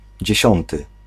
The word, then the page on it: tenth: /ˈtɛnθ/